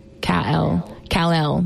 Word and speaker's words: Kal-El: I think it's Kal-El. Kal-El. That's how you say it.